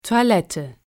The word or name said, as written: Toilette